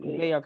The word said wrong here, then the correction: Bexar: County. Is that how you say it? – Bexar.